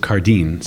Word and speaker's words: Cardijn: In near Brussels, his name was Joseph Cardijn, C-A-R-D-I-J-N.